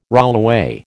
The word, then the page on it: rollaway: American English